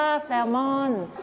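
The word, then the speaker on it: salmon: แซลมอนภาษาอังกฤษอ่านว่าแซลมอนไม่ออกเสียง L นะแซลมอนนะคะภาษาไทยใช้คำว่าปลาแซลมอนแต่ภาษาอังกฤษไม่ใช่แซลมอนนะคะ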